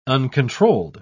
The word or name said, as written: uncontrolled